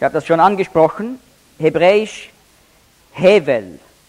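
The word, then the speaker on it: Hevel (הבל): Auf Hebräisch spricht man das aus, Hevel.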